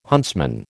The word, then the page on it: huntsman: American English